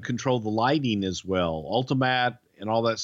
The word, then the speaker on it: Ultimatte: If you'd like to see it today, it's called Ultimat and it's spelled Ultimatte.